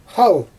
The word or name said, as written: xal